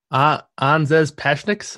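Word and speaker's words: Anžejs Pasečņiks: Pashinik. You say the name. How do you say it? – Anzez Pashniks. That's there you go. You got it.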